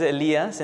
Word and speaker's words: Elias: For those of you that have a hard time saying my name is Elias in Spanish. It's Elijah when you translate it to English. But if it's easier for you, you can say Elias.